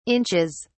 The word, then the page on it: inches: British English